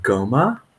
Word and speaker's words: goma: So in Japanese, this is called goma. And it actually comes from Indian traditions. It was somewhat adopted into Buddhism from Hindu traditions, or it's called homa, slightly, just a slight difference in pronunciations.